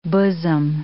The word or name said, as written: bosom